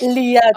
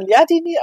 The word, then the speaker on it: Lyadini: Liadini. – Okay, krass. – Liadini, also, nicht Ladini, sondern Liadini, aber davor war es richtig.